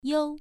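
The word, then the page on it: you: tone 1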